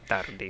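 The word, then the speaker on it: Tardi: know how exactly to pronounce it. – Tardy.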